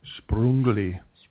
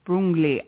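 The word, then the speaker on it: Sprüngli: There's another place that we went called Sprungli. – Sprungli. I keep saying it wrong.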